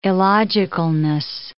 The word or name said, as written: illogicalness